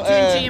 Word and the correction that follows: Tunche: Since the name is in Spanish, it's pronounced Tunche, but we don't mind.